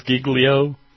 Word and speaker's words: Giglio: Giglio? It really is Giglio? I thought I was just mispronunciating the Italian. I thought I might be Giglio. But no, apparently it is, really.